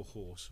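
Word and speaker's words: Hause: I think with Scott Goldbourne in for house or horse or. – Horse, I believe the correct pronunciation on that one is.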